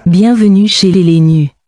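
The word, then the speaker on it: Bienvenue chez les nus: Pero mejor, pronúncialo tú mejor, así que suene bonito en francés. ¿Cómo sería? – Bienvenue chez les Lenus.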